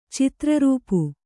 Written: ಚಿತ್ರರೂಪು

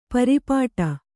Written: ಪರ್ಪಟಿ

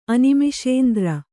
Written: ಅನಿಮಿಷೇಂದ್ರ

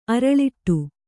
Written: ಅರಳಿಟ್ಟು

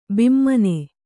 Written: ಭಾಮಿನಿ

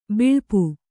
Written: ಬಲ್ಪು

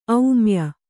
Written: ಔಮ್ಯ

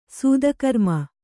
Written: ಸತ್ಕರ್ಮಿ

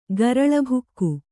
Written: ಗರಲಭುಕ್ಕು

